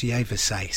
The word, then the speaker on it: Yvaes: I'm going to take a guess at pronouncing the name. It's Yevase. It's spelt Y-V-A-E-S. But I'm told it's pronounced Yevase.